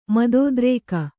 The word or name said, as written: ಮದೋದ್ರೇಕ